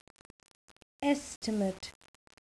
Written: estimate

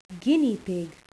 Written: guinea pig

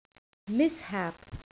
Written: mishap

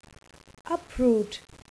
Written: uproot

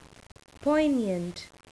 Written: poignant

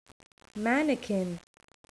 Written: mannequin

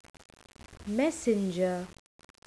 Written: messenger

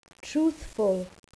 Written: truthful

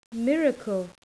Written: miracle

